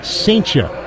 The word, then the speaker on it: Cijntje: Gerangelo Saintia's last name is spelled C-I-J-N-T-J-E. Pronounced Saintia.